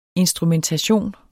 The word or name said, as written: instrumentation